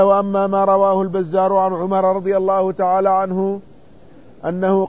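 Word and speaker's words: عمرو: مثل كلمة عمر يكتب فيها واو زائدة ولا تنطق.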